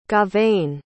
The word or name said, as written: gauvain